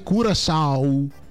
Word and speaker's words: Curaçao: Vedi, mi dice: si legge Curaçao con au finale.